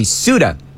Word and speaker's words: Ceuta: All right, so we'll call it Ceuta from now on. S-O-O-T-A, Ceuta.